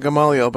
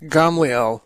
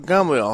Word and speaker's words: Gamaliel: That's how we usually pronounce it, Gamaliel, but I noticed in Hebrew it's pronounced Gamaliel. The middle syllable in Hebrew is silent, so Gamaliel, I'll pronounce it the way, at least I think they pronounce it in Hebrew.